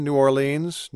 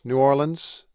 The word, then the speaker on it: New Orleans: Pick your pronunciation. New Orleans, New Orleans, Nolens, New Orleans.